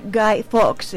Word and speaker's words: Guy Fawkes: Siguiendo con la trama, durante todo ese año ve un rostro escondido detrás de la máscara de Guy Fawkes. No sé si así se pronuncia. – Debe ser Guy Fawkes, el de los fuegos artificiales, sí.